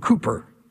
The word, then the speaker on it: Cowper: Centuries after Esther lived, and years before James Hastings, there lived a man named William Cooper, spelled C-O-W-P-E-R, often pronounced Cowper.